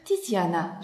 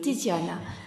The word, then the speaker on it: Tiziana: Aujourd'hui, je suis dans le studio avec Tiziana. J'ai bien prononcé ? – Oui, bien prononcé. Oui. – Tiziana, très bien.